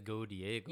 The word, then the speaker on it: Godiego: It's not Go Diego. It's like G-O-D-I-E-G-O. – Yeah. If you say it like it's a Japanese name, It'd be Go Diego. – But it's but, Go Die Go. – Yeah. – Right. – Go Die Go, English. Okay. English name.